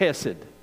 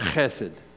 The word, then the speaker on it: chesed: Or really the way to pronounce it, you almost have to clear your throat to say the Hebrew word is kind of like chesed. That's the way you say it, chesed.